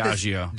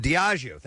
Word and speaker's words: Diageo: Diageo, is this how you say it? – Diageo. – Diageo, thank you very much.